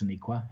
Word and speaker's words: Sonequa: I think it's Sonequa. Have you heard any different pronunciation? – No, it sounds about right to me.